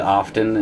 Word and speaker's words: often: Also, I said often instead of often. I want everyone to know that T is silent.